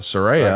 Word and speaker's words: Soraya: I think we've all been saying it incorrectly, not Soraya, but Soraya. – Right. Soraya's right.